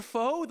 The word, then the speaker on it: pho: Be like, want to go for pho? They're always like, it's pho. It's not pho, it's pho. Say pho, it's pho. It's not pho, it's pho. It's pho, actually, it's pho.